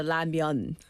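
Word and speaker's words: Ramyeon (라면): Anybody in the U.S. that's listening, what do you say? What's your... – They say ramen. – Ramen. – Ramen. – Still. – Right, still. – Okay, got it. – Because that's how it's spelled. – But I don't say that anymore. – What do you say? – Ramyeon. – Of course, the myeon, the noodles.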